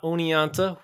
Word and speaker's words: Oneonta: One last thing before we get going, you'll hear me pronounce the name of the gorge with a T throughout this podcast, as in Oneonta. Now, there are others that pronounce it without the T, as in Oneonta. I've definitely heard it both ways,